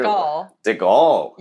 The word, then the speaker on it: DeGuelle: How do you pronounce it? – DeGuelle. Yes.